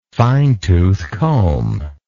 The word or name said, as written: fine tooth comb